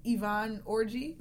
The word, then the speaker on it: Yvonne Orji: And she was up against Yvonne Orji.